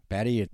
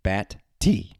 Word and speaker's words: Batty: Yes, it's not Batty. It's not Batty. I don't know if anyone's called him Batty, but it's Batty. You put the stress on the T at the end, and I'm sure that other football teams will be stressing to kick it away from that guy.